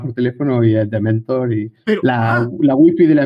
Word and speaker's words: DMNTR: No es Dementor, quítale las vocales, pero claro, es impronunciable. ¿Cómo lo pronuncias cuando alguien te pregunta cómo se llama tu newsletter? – No, yo siempre digo Dementor.